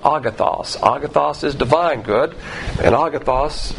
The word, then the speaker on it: agathos: agathos, A-G-A-T-H-O-S. You can pronounce this word agathos or agathos. I like the southern version pronunciation. Even though it's an O, you pronounce it with an A.